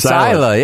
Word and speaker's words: Isla: It's Isla. Yeah, the S is silent.